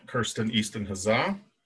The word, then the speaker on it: Kirsten Easton-Hazzaa: Today I'm joined by playwright of Trixxxie 2.0, Kirsten Easton-Hazzaa.